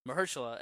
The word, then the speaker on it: Mahershala: You said Marshala? – If I've heard it said right, Mahershala? – Mahershala.